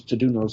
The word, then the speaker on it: Taduno: A book that didn't make the Nomos, which I thought was very good, was Tadunos Song. Sorry, my pronunciation is gone. Odafe, God help me. Taduno, T-A-D-U-N-O,